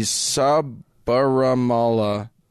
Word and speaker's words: Sabarimala: It is in the Sabarimala Temple.